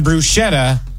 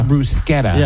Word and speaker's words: bruschetta: And then bruschetta is not actually bruschetta. – It's bruschetta. – It's bruschetta.